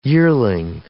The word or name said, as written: yearling